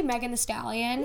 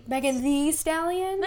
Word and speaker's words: Megan Thee Stallion: So I say Megan Thee Stallion. – Yeah, that's what it is. – But it's spelled T-H-E-E. Megan Thee Stallion.